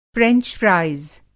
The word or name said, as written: फ़्रेंच फ़्राइज़